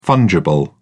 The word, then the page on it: fungible: British English